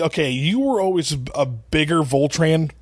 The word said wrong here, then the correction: Voltron: Voltran, Voltron fan. – Obviously, kid can't even pronounce it correctly.